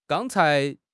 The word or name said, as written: gang1 cai2